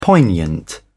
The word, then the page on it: poignant: British English